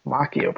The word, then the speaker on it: Macchio: Macchio? I don't know how to pronounce that. Macchio, probably. Like Macchiato.